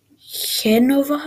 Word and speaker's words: Genova: I went to the biggest aquarium in Europe in Genova, I think that's how you say it in Italian, or Genova in English.